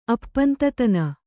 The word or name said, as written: ಅಪ್ಪಂತತನ